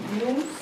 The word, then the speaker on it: Nous: We're going to start from telling you about the name of the public chewing company, which can be pronounced in two different ways. Nous, like the intellect in ancient Greek, and nous, the first person plural in French language.